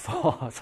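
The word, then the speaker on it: phở: 이제 우리가 배웠으니까 베트남 식당 가면은, 베트남 음식점 가면은, 퍼 주세요. F 발음. 퍼.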